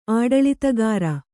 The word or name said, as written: ಆಡಳಿತಗಾರ